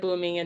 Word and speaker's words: Bumi: I don't know if it's Kya, Bumi, and Tenzin. – Yes, Kya, Bumi, and Tenzin. Those were Aang and Katara's kids.